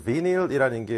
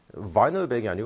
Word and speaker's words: vinyl: 왜냐하면 일단 비닐이라는 게 vinyl 발음적으로 비닐이 아니고 vinyl이기도 하고요. 그리고 우리가 쓰는 비닐봉지는 실제로 vinyl백이 아니고 plastic백입니다.